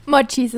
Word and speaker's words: Mochis: Mochis oder Mochis? – Mochis ist richtig.